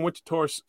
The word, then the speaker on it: Winterthur: So you grew up in Winterthur, Switzerland. Did I say that right? – Exactly.